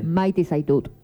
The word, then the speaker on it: Maite zaitut: Maite Zaitut. – ¿Maike? – Maite Zaitut. – A ver, Maike Zaitut. – Estamos. – Maite. – Maite. – Maite. – Como si fuese un nombre mujer.